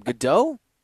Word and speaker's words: Gadot: How do you say her name? Gadot? – I believe that's right.